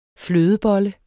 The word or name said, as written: flødebolle